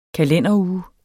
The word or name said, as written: kalenderuge